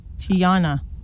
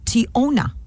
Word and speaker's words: Tyonna: T-Y-O-N-N-A. – Tiana. – I would have called her Tiana. – Yeah. Okay. – Or Tiana. Tiana or Tiana. – Okay. – Her name is actually pronounced Tiona. Tiona. – Oh, silent Y.